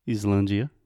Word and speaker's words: island: So there is an S right there in the beginning, but we don't say that. so this is kind of similar to Iceland, the country, Islandia, right? Yes. But with Iceland, we do pronounce the S sound. – But it's not an S, it's a C. – Yes, correct. So we say island.